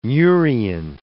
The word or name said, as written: murein